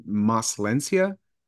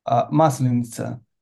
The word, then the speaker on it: Maslenitsa: Did you call the celebration Maslencia? – Maslenitsa.